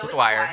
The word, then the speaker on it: Dwyer: Sorry, did I say Dwyer? It's Andy Dyer. – It is Dwyer. – It's Dwyer. Okay.